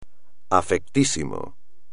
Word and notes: afectísimo: espagnol

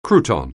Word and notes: crouton: anglais

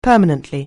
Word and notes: permanently: anglais

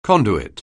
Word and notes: conduit: anglais

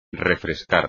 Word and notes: refrescar: espagnol

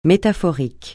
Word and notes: métaphorique: francais

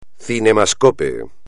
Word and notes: cinemascope: espagnol

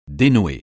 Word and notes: dénouer: français

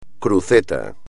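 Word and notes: cruceta: español

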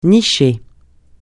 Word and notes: nicher: francais

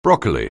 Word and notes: broccoli: anglais

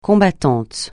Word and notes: combattant: francais

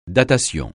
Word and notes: datation: francais

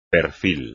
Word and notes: perfil: espagnol

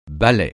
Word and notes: balai: francais

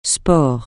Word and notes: sport: francais

